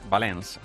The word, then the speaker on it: Valence: Valence.